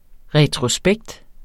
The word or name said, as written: retrospekt